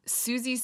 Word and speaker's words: Siouxsie: If you want to look her up, it's Susie spelled like the Native American tribe. – S-I-O-U-X-S-I-E.